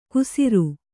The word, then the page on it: ಕುಸಿರು: kusiru